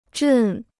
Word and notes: 阵: zhèn

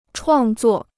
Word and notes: 创作: chuàng zuò

